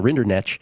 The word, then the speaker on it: Rinderknecht: Once again, Rinderknecht is how you pronounce that, actually.